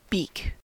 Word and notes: beak: American English

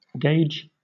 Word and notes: gage: British English

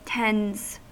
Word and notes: tends: American English

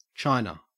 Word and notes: china: Australian English

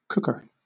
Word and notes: cooker: British English

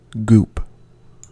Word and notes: goop: American English

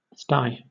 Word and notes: sty: British English

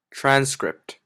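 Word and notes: transcript: Canadian English

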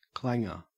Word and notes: clanger: Australian English

